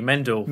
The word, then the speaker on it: Mendel: We had Mr. Medal, or what it... Medal? – How dare you, Mendel. Mendel. Get his name right.